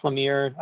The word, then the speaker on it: Lemire: Jeff Lemire.